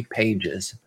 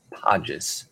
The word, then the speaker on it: Pages: His name looks like it says Andy Pages, but it's Podges. is how you pronounce that.